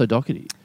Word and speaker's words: O'Doherty: I feel bad because after we recorded it, someone said, you know it's O'Doherty. It's pronounced O'Doherty. – Yeah, sure, maybe. – Okay. – But also the H is in there.